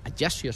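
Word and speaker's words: Ajaccio: Ayer jugó el partido como, como titular frente al Ayasio, se pronuncia.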